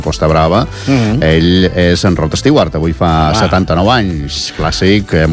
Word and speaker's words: Rod Stewart: Rod Stewart.